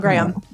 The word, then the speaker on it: Graham: and I loved it because my parents are from the southern U.S., so actually they say my name the British way. – That's right. – Two syllables on Graham because of the drawl. So there you go. – But up here in Michigan, where I have been raised, it's a single syllable because our accent is as flat as the land.